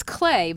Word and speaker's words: Clet: Well, because I just looked it up, and you say it's clay, but it's spelled C L E T. – Yeah, but it's pronounced clay.